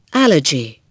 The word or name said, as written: allergy